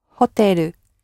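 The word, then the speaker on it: Hoteru (ホテル): and that's the closest that the Japanese get to saying hotel, the L at the end. – Hoteru.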